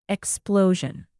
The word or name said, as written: explosion